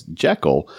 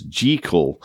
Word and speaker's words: Jekyll: Every single one of us, me included, pronounces the name of the doctor as Jekyll, but Robert Louis Stevenson would have pronounced it as Jekyll. He was Scottish, and it's a Scottish name. Apparently, it was mispronounced in an early movie version, and we've been all saying it wrong ever since.